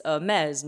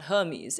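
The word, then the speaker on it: Hermès: It's Hermes, not Hermes.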